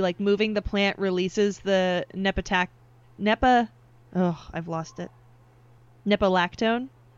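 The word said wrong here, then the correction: nepetalactone: Nepatalactone. There's another syllable in there.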